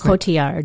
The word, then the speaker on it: Cotillard: How do you say her last Cotillard.